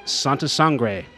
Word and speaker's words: Santa Sangre: And also, we're talking about Santa Sangre.